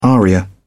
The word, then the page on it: aria: British English